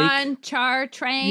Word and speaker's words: Pontchartrain: Pontchartrain.